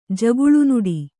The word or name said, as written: ಜಗುಳುನುಡಿ